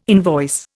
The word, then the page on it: invoice: Indian